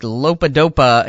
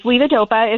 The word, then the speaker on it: levodopa: There is a medication that I laugh because my mother-in-law calls it like Lopa-Dopa. It's something like that. But that's been known to, it's not curable, but it's controllable, correct? – That's correct. It's levodopa is how I pronounce it.